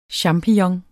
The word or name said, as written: champignon